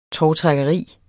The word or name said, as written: tovtrækkeri